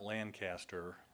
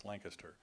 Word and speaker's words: Lancaster: Did I say that right? It's Lancaster? – Yep. – Yeah, it's not Lancaster. I learned that this year when I was up there. It's Lancaster, Pennsylvania.